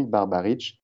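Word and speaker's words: Barbarić: S-L-A-V-K-O. – S-L-A-V-K-O. K-O. Voilà. – Plus loin, son nom de famille, Barbaric. Barbaric. Voilà. B-A-R-B-A-R-I-C.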